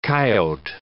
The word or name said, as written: coyote